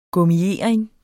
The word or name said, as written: gummiering